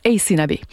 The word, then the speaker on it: Aysanabee: Et là, vous êtes nombreux à nous avoir écrit pour nous demander qui était donc Ace Nabé, comment on fait pour retrouver sa musique. Alors, je vous épelle, son nom, c'est A-Y-S-A-N-A-B-E-E. Ça se prononce Ace Nabé.